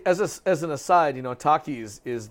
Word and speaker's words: Takis: Takis, yeah.